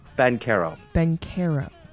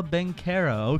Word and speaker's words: Banchero: Paolo Banchero. – Banchero? – Si. – Paolo Banchero.